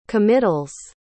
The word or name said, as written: committals